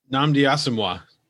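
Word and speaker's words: Nnamdi Asomugha: I was impressed by, Asamuga? Muga? Say his name? The football player? – Nnamdi Asamoah. – Nnamdi Asamoah. Wait. Asamoah? – Is that how you say Asamoah, yeah.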